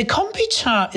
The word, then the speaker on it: kombucha: So it's a short U, kombucha. – That, yeah, kombucha. Yeah, okay. Kombucha, because all their syllables are short in Japanese.